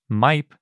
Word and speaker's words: Maip: This new Megaraptorid is named MIPE, I think is how you pronounce it. It's spelled M-A-I-P. That's basically how you pronounce it in Spanish and English.